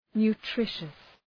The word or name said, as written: nutritious